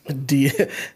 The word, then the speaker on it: Dyatlov: That's how you say it, right? – Dyatlov.